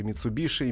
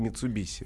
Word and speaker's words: Mitsubishi: Э, ну да, два, два варианта произношения, оба правильных. И Митсубиши и Митсубиси.